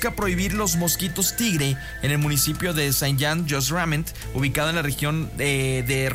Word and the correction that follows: Saint-Just-Saint-Rembert: No sé si lo dije bien. Saint-Just-Saint-Rembert, ahí está.